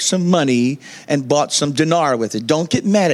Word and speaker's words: Dinar: I, how you say it? Dinar.